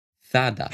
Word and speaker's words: Zara: Zara, und ich bleib dabei, wird tatsächlich Zada ausgesprochen. und ab jetzt wird es eh schwer, weil ASOS, das kennst du bald, oder? – Ja, hast du nicht ASOS, hast ASOS.